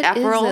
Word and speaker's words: Aperol: But she's clearly been drinking the What's Hot to Drink blog because she orders an, how do you say it? – What Aperol? – Is it? Yeah.